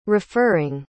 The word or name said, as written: referring